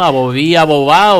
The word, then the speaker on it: abobí, abobá: Este tema de abobí, abobá, ¿se pronuncia así, no? ¿Abobí, abobá o cómo, Rafa? – Sí, sí, abobí, abobá, simplemente abobí, abobá.